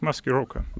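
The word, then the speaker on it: Maskirovka (маскировка): How do you pronounce it? – Correct, Maskirovka. – Maskirovka.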